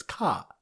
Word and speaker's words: car: So the Sim Daf Chafal is a car which Bostonians pronounce as Ka.